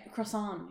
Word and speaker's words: croissant: Croissant. You're not supposed to pronounce the T. – Croissant.